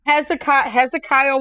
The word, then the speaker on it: Hezekiah: So how do you say Hezekiah? Am I saying that right? – Hezekiah Walker.